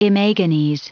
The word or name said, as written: imagines